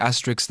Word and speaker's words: asterisk: I was today as old when I realized that I've actually been pronouncing asterisks the wrong way. Rory made a good point. It was because of the cartoon. That's what messed us up. This is how you pronounce asterisks. – Asterisk. – Asterisk. I have been saying asterisks my entire life.